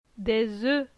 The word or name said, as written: Des oeufs